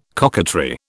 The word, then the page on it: coquetry: British English